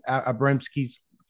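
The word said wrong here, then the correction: Abramitzky: full name? I don't think I said it right. – Ron Abramitsky.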